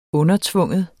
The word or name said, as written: undertvinge